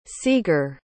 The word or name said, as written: seager